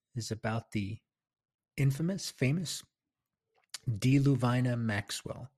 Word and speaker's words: Deluvina: That's the way I've determined I'm going to pronounce it, Deluvina. In my first film, Billy the Kid in the Lincoln County War, I used Deluvina, but I'm changing it.